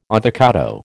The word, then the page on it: avocado: American English